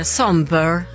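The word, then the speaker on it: sombr: Homewrecker med Somber ska det uttalas tydligen.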